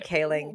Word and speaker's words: Kaling: Now, in the original inception of this series, Mindy Kaling, I keep mispronouncing her name because I'm an asshole. Mindy Kaling.